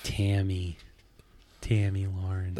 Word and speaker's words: Tammy Lauren: No, she'll be like Tommy Lauren. Yeah. That's what she reminds me of. – Tammy Lauren.